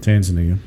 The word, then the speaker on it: Tanzania: Tanzania, is that how you say it? – Tanzania.